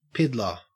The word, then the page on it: piddler: Australian English